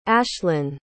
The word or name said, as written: ashlin